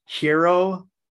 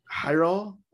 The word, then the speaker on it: Jairo: I don't know how to pronounce that name, man. J-A-I-R-O, Hiro. – Hiro.